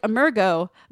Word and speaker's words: Emergo: It's spelled E-M-E-R-G-O. Which would Emergo. – Emergo. See, when I read it, it's like Emergo, but like it's got to be Emergo.